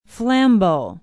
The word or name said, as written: Flambeau